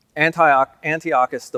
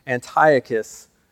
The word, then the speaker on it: Antiochus: Now, Antiochus IV, he changed his title to, well, it's actually Antiochus, I'm sorry, I pronounced that wrong, Antiochus Epiphanes.